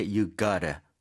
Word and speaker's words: gotta: 이거 그래서 발음할 때 you gotta 이렇게 하는 사람들도 많죠. – 어, 맞아요. 아예 right. v 소리도 빠지고 you gotta.